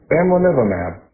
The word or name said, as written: bamlanivimab